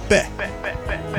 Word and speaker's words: Paix: Catherine Ribeiro and Alps did Peh. I had to look up. How do you pronounce that? It's spelled P-A-I-X. Apparently it's Beh. It means peace.